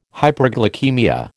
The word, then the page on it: hyperglycaemia: American English